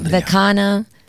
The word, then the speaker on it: Vikina: There's no Enya. People love to say Enya. – Vikina. People butchered the pronunciation. Vikina. – Vikina. – Vikina, or they say Vikina, like if it's a piña.